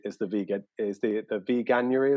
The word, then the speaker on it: veganuary: So what come up quite a lot recently because it's been relatively close to January is the veganuary, is that how you pronounce it? – Yeah.